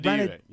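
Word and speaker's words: Ranadivé: Ranadive, yeah.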